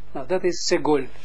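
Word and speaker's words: segol (סגול): Moeten we zo uitspreken als segol. Segol. Segol. Okay. Bij hem staat het segel. Maar goed, het is niet erg. Het is niet verkeerd. Maar liever moeten we segol uitspreken.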